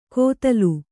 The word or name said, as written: ಕೊದಲು